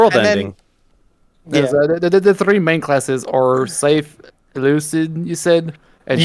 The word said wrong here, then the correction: Euclid: I think it's pronounced Euclid? – Euclid? Yeah. – Yeah, it's Euclid.